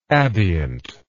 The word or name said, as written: abient